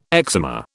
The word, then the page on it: eczema: British English